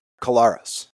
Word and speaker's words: Kolaris: The middle syllable in Zach's name is just the letter R, Kolaris. If you say the R in the middle, then you got it.